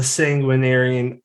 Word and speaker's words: Sanguinarian: Brendan, like, lead us into the talk of the Sanguinarian Id. I said it right. Yes. – You got it.